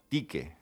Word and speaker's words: Tique: Tique. – Tique, ¿ves? Pues no lo había oído nunca. – En latín se escribe Tiche. – Tiche. – Tiche con Y, griega, pero se pronuncia Tique.